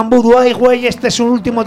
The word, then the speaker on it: Voodoo Highway: Voodoo Highway.